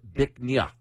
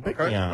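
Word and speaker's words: Bích Ngà: It's spelled B-I-C-H-N-G-A. It's pronounced Bích Nha, which loosely translates to beautiful jade. – Oh, Bích Nha.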